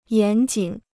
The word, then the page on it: 严谨: yán jǐn